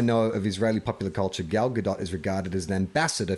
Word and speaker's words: Gal Gadot: It's Gal Gadot. We were saying it's Gal Gadot. – Gal Gadot. – Is that right? Yes, it's Gal Gadot.